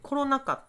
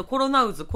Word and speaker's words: コロナ禍: 私結構ね、ラジオで噛んだりとかね、あの前回ね、コロナ禍って、あのコロナに渦って書いてコロナ禍って読むんですよ。で、あれをずっとコロナ渦、